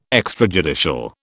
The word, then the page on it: extrajudicial: British English